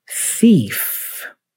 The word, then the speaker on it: thief: Thief. Also kind of tricky because you start with the tongue out, thi, but then the tongue needs to retract to pronounce the F at the end. The F has no tongue out. It's you know? Your upper teeth touch your bottom lip. So thief.